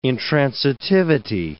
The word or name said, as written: intransitivity